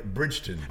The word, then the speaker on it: Bridgeton: Did I say that right? – Yeah, Bridgeton.